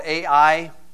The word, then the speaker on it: Ai: Example, back in Joshua chapter 8, there's a little town called Ai. It's a funny town. You spell it every time you say it, Ai.